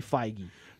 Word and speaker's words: Feige: I understand it's pronounced Feige, but... He is a huge mass... I listen to podcasts from Hollywood, thank you very much. And they say Feige.